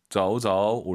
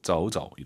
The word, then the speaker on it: 走走: 대신 두 번째 동사는 경성이에요. 한 글자일 때. 走走 올리지 마시고 走走 이렇게 발음하시면 좋을 것 같습니다.